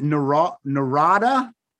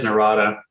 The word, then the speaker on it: Narada: Is it Narada? – I think it is Narada. – Narada Michael Walden.